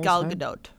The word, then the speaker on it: Gal Gadot: He was pronouncing it Gal Gadot, and I'm like, am I pronouncing it wrong? It is Gal Gadot. Sorry, I feel bad. I'm like, I've been saying Gal Gadot this whole time.